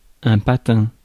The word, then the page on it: skate: /skeɪt/; [skeɪt]